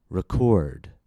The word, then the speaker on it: record: R-E-C-O-R-D as a verb is pronounced record, record.